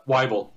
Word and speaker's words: Weible: Rick here, it's, how do you say your last name? Weeby, right? Weeby. – Yep. Weible.